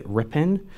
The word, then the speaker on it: Ripon: Yeah, it was called, I called it Ripon, but I got corrected a while ago by a member of Congress that I should pronounce it Ripon, because... – It's R-I-P-O-N.